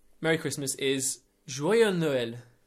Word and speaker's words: joyeux: Joyeux Noël. – Joyeux. – Joyeux. – Joyeux. – Joyeux. – Joyeux Noël. – So if you think of joyeux, it's spelled like J-O-Y, so like joy.